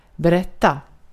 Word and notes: kertoa: /ˈker.to.ɑʔ/

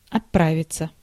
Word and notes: gaan: /ɣaːn/

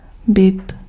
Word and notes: bit: /bɪt/

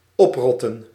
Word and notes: oprotten: /ˈɔpˌrɔt.ə(n)/

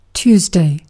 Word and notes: tiistai: [ˈt̪iːstɑi]; /ˈtiːs.tɑi/

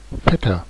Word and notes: cousin: [ku.zɛ̃]

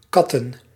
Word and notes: katten: /ˈkɑ.tə(n)/

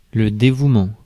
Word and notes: attachment: /ə.ˈtætʃ.mənt/

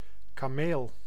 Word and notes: kameel: /kaːˈmeːl/; /kɑ'mel/

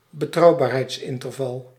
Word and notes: betrouwbaarheidsinterval: Dutch

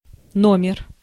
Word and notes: issue: /ˈɪʃ(j)uː/; [ˈɪ.ʃjuː]; /ˈɪ.sjuː/

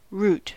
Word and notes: route: English

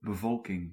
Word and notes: population: American English

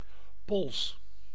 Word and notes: wrist: American English; [ɹɪst]

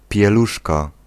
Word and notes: diaper: [ˈdaɪ.pɚ]; /ˈdaɪəpɚ/

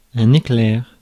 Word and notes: éclair: /e.klɛʁ/

